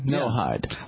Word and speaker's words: Noahide: I called in to discuss what they call the no-eyed laws. Now, that's spelled... – The no what? – Okay. It's spelled N-O-A-H-I-D-E. It has Noah in it. – Okay. No yeah. Hide.